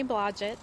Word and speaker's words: Blodgett: Kimberly Blodgett.